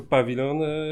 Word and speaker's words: pawilon: Super, e, e, super e, akcentujesz słowo pawilon. Tak, bo sami, sam Netflix akcentuje właśnie to jako pawilon.